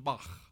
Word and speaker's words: Shabbat: And if you say it right, you got to kind of get a kernel of popcorn in your throat and say, Shabbat.